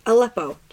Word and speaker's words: Aleppo: Why can I not pronounce Aleppo. – It? Aleppo.